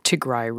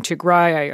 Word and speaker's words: Tigray: Looking ahead, soldiers from the northern Tigray region, and by the way, I'm saying Tigray, I hear you saying Tigray, these are both acceptable pronunciations, yeah.